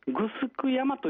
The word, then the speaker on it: 城山: 伊江島の中心から少し東寄りのところに、城山と漢字で書いて、グスク山という山がありましてね。